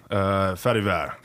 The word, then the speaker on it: Farivar: Farivar.